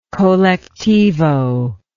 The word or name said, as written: colectivo